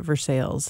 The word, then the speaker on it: Versailles: We say it back there just as they do here in Missouri, Versailles, spelled like Versailles, but Versailles,